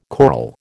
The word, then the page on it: choral: American English